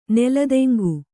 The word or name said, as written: ನೆಲತೆಂಗು